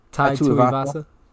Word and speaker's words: Tai Tuivasa: So Tua versus, actually, how you pronounce his last name? – Tai Tuivasa.